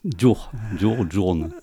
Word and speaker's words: Jorn: Et c'est les éditions, alors je vais essayer de bien prononcer, les éditions Journe. – Voilà, Journe. – Voilà, Journe, Journe en français, hein, J-O-R-N.